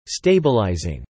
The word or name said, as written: stabilizing